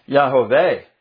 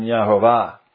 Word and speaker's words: Yahweh: It's pronounced Yahweh in the Hebrew rather than Yahovah.